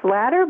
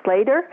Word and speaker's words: Slatter: And Jean Slater is spelled J-E-A-N. S is in Sam, L-A-T-T-E-R. So it looks like it's Slatter, but it's Slater. And I tried to get my husband to drop one of those T's, but it never worked. So I always have to explain how to pronounce my last name.